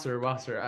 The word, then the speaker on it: Wasser: Am I saying it right? – Wasser.